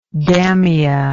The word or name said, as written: Damia